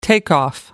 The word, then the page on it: takeoff: British English